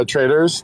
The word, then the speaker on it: Tradooors: The next people we have up, looks like it's the Tradors. Am I saying that right? – Yeah, Traders. Thanks, guys.